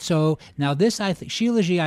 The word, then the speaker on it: shilajit: And so I pronounce it shilajit. Is that how you pronounce it? – Got it. – Okay, and we say like shilajit, you know that poem, shilajit. So that's how we say shilajit.